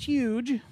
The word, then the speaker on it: HUG: It's spelled H-U-G Entertainment. – Doesn't that mean Hug Entertainment? – It's pronounced Huge.